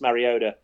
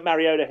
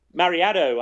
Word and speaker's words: Mariota: As for Mariota himself, I'm sure he'll get over the fact that the commissioner, Roger Goodell, failed to pronounce his surname correctly when he was selected in the draft, but I don't even know what he said. – Mariado, I think, was the way he pronounced it, which was interesting.